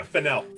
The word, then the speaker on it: Fennell: Yeah, Fennell. – Fennell.